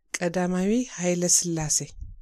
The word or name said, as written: Haile Selassie